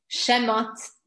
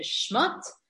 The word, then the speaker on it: Shemot: Shemot, the second book of Torah is Sefer Shemot, not Sefer Shmot.